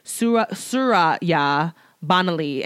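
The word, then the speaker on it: Surya Bonaly: Suri Bonaly, oh my, which I probably completely butchered her name. Mm-hmm. I'm so sorry. It's Suraya Bonaly.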